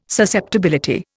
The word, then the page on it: susceptibility: Indian